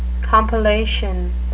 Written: compellation

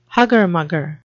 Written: hugger-mugger

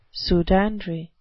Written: pseudandry

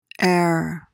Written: ere

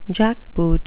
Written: jackboot

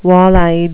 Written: walleyed

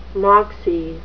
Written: moxie

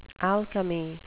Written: alchemy

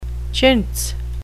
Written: chintz

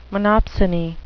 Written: monopsony

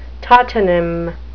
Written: tautonym

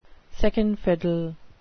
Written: second fiddle

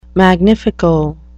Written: magnifico

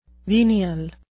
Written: venial

